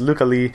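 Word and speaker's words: Lykke Li: Now 100% properly pronounced, Luca Lee, according to a listener.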